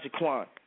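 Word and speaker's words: JeQuan: It's spelled Z-A-V-E-O-N. Space J-E, capital Q-U-A-N, and that's Xavion Jaquan.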